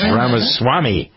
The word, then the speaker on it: Ramaswamy: and Jennifer was telling me, no, no, it's this way. It's Ramaswamy.